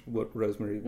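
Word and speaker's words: Rosmarie: Sorry so much, Rosemary. – Maybe it's, no, I think it's pronounced, I hear it is too, but I think it's pronounced Rosemary.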